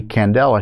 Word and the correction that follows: Yandle: I think it is, or Candle, let me see. Excuse me, Stacy M. Yandel, Yandle.